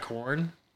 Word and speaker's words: Quorn: So, like, you ever had corn? – Corn. – I'm sorry. The it's spelled Q-U-O-R-N. It's like a meat alternative.